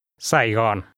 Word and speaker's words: Sài Gòn: In Vietnamese, this name is pronounced with 2 low falling tones. – Saigon.